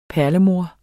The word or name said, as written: perlemor